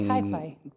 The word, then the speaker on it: hyphy: Que es H-Y, P-H-Y, nunca lo escuché. ¿No es Hi-Fi? Mm, es hi-fi.